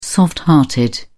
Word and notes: soft hearted: British English

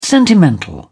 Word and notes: sentimental: British English

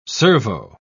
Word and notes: servo: British English

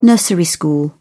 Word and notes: nursery school: British English

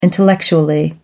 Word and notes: intellectually: British English

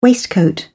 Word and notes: waistcoat: British English

